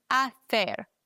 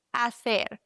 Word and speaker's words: hacer: Achtung, das H wird nie ausgesprochen, ja? Das ist ein stummes H. Deswegen hacer, nicht hacer. Ganz, ganz wichtig, hacer.